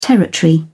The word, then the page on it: territory: British English